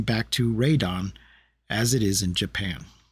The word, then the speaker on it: Radon: Yeah, it says here on Wikipedia in past English releases, including dubbed films and video game titles, Rodan's name was always pronounced Rodan. In Japan, however, his name has always been pronounced and spelled in katakana as Radon.